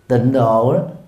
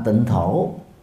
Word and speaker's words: tịnh thổ: tịnh độ đọc đúng là tịnh thổ